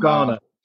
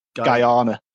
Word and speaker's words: Guyana: I just wasn't sure how to pronounce it first. – Not Ghana. – No, not Ghana. Guyana.